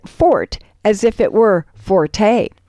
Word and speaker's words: forte: But if you means one's long suit, the thing someone does best, pronounce the word like it's spelled, fort, and just leave off the rest.